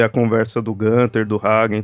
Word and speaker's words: Hagen: Esse rei ele tinha uma irmã chamada Gutrune e um meio-irmão chamado Hagen. – Hagen, para saber como é que fala é só lembrar do sorvete. hagen das.